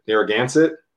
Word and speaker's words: Narragansett: Narragansett? – Yes.